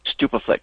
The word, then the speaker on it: Stupeflix: So it was Superflix? – Stupaflix. STUPEFLIX.